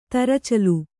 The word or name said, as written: ತರಚಲು